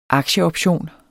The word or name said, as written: aktieoption